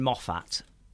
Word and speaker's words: Moffat: But they're supposed to have fixed the actor because Stephen Moffat, that's how you pronounce him.